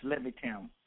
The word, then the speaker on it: Levittown: Levittown, Pennsylvania? – Levittown.